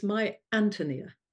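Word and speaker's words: Ántonia: It's supposed to be pronounced, it's very difficult to say, but it's My Antonia. – Oh, the accent is on the A, is on the Antonia. First A, Antonia.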